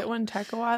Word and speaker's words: Wintekawa: Anyways, so the word Wendigo probably comes from the Proto-Algonquian term, and I'm going to try to say this correctly, Wintekawa? – That's right, Wintekawa. That's how I would say it.